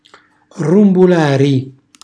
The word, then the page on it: rrumbulari: Calabrese